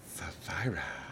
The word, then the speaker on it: Sapphira: I pronounce it Sapphira, but yeah, I think you're right on that. Like, I don't know. – No, I do. I think you're right. I think that's how it's pronounced.